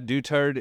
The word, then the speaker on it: Dutertre: Yeah, Dutertre is the right pronunciation for all those weird E's and R's.